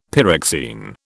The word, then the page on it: pyroxene: British English